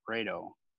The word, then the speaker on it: Rayto: Well, as you know, well, I used to, my birth name is Rayto. – How do you spell that? – R-A-Y-T-O.